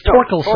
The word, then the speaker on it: Thorkelson: Well, actually, the H is silent. – It's Olson? – No. – Oh, it's Torkelson. – Torkelson, yes. Oh, yes.